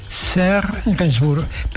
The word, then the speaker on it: Serge Gainsbourg: ¿Lo pronuncio bien? Serge Gainsbourg, perfecto.